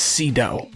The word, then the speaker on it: Sydow: I think it's I've always heard it pronounced Sydow.